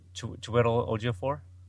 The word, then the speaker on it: Chiwetel Ejiofor: Chiwetel Ejiofor. – Is that how you say it? – Chiwetel? Yeah, I think it's Chiwetel Ejiofor.